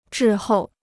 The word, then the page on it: 滞后: zhì hòu